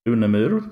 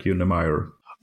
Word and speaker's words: Unemyr: In Swedish, I pronounce it Unemyr, but in English, it usually becomes something like Unemyr.